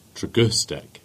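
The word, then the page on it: Tregustick: Cornish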